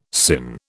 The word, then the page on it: sinh: British English